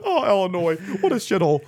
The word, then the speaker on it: Illinois: For those of us that say it correctly, it's Illinois. – What is it? – Illinois. – Illinois? Yeah. – Ill. Not L. – Illinois? – Just like it's milk.